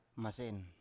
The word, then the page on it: masen: Biak